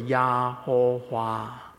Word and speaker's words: 耶和华: 所以如果你会闽南语的话，闽南语福建话真的是这样发音的，耶和华是 Yahowah。